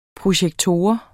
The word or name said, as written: projektor